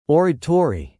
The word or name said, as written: oratory